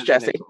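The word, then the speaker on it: Djesse: I know that the D is silent and it's supposed to be. Yeah, it's Jesse.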